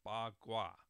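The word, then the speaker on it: Pa Kua: And so, K-U-A. I'm sorry, I'm getting my K's and my Q's mixed up. K-U-A, and that's actually pronounced Bagua. So very different than the way it's actually written.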